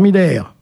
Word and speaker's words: Miller: Gérard Miller, chroniqueur, à prononcer à l'allemande, Jean-Luc Morandini, animateur, Patrick Poivre-d'Arvor, journaliste, Tariq Ramadan, islamologue.